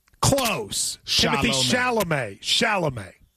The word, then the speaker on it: Chalamet: You never once managed in that segment to say it correctly. You said sham. You said charm. You said... – It's confusing, though. – Timothy what? – Well, now I know it's Chalamet. I know it's like the whole... – Okay, so yeah, well, that's three syllables, but let's put them all together into a word.